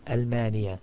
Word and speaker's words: ألمانيا: ألمانيا, Germany, في ألمانيا, من ألمانيا, إلى ألمانيا, and there is no E sound at the end. As we said earlier, you say that there is an كسرة at the end, but it does not show up, neither in pronunciation nor in writing.